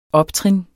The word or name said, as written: optrin